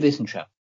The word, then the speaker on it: Wissenschaft: Die Fröhliche Wissenschaft. – Say the last word one more time. – Wissenschaft. The W in German is pronounced V.